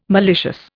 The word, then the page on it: malicious: Indian